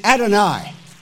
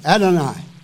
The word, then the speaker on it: Adonai: And if we pronounce that in English, we would say Adonai. That from the Hebrew, Adonai.